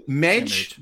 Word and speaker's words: Mage: Mage, Mage, just simply Mage. Like that.